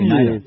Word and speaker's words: Hnida: How else would you pronounce it? H-N-I-D-A, Nida.